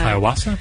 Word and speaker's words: ayahuasca: It's it's uh, ayahuasca.